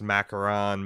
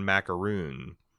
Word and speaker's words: macaron: That he kinda is the type of dude that, you know, might pronounce macaron macaroon.